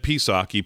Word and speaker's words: Psaki: Jen Psaki, P is silent.